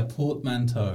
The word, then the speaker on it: portmanteau: Yeah, portmanteau. – Portmanteau. – That's how we usually pronounce it.